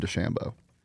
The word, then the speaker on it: DeChambeau: Like, I've never heard him pronounce it any other way than DeChambeau.